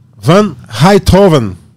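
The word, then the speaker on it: Rijthoven: O, pronúncia certinho de novo para mim. – O Tim Van Heithoven.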